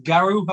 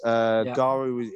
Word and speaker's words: Garw: And the other one I was going to mention to you was one in Wales, pronounced Garw, I believe, G-A-R-W.